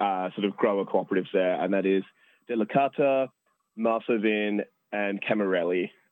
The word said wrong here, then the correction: Camilleri: Oh, sorry, Camilleri. Definitely pronounced. – Do you mean wrong. – Camilleri? – Camilleri, maybe. Yes.